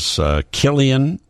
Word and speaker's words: Cillian: He is Cillian. That's how you say his name, C-I-L-L-I-A-N, Cillian Murphy, who plays Robert Oppenheimer.